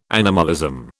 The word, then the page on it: animalism: British English